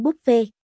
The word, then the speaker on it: buffet: Đi ăn buffet, đọc là buffet theo kiểu tiếng Pháp, chứ không phải buffet theo tiếng Anh đâu nha.